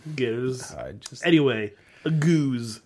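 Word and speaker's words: gueuze: How do you say goose? – That's closer than... – it's not Huzza. I made sure to pronounce the G this time, because now it's seared in my memory that we were wrong.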